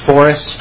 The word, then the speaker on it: Forrest: Hello, my name is Forrest, spelled with two R's, Wilkinson.